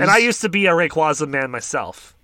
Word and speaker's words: Rayquaza: I will never say Rayquaza because that it's gross. It's Rayquaza. – Well, I don't know. But you see, here's the thing about Rayquaza's pronunciation. Because I used to be a Rayquaza guy, but then I became a Rayquaza guy. It's because you pronounce it's Quasar or Quasar. – Yeah, I know. – And that's where, like, where the naming origin kind of comes from.